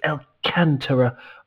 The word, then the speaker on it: Alcantara: Alcantara, the accent is on the second syllable, A, the second A, so it's Alcantara, I believe, but I'm going to check that, because it's Portuguese, and I don't know Portuguese.